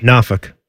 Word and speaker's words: Norfolk: How do you pronounce the city that starts in Virginia? N-O-R-F-O-L-K. – Norfolk. – Okay. – Norfolk. Not Norfolk. Yeah.